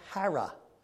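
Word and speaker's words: chara: The Greek word for joy is actually pronounced hara, H-A-R-R-A.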